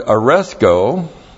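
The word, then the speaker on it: aresko (ἀρέσκω): And then in the New Testament, we have the Greek word aresko. A-R-E-S-K, long O.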